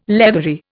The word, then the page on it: leathery: Indian